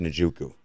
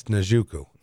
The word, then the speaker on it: Njoku: You say his name. – David Njoku. – Yes, Njoku.